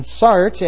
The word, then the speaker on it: Sartre: And Sartre, as his name is pronounced, embodied what it was to be left, to be socialist, and to be politically liberal.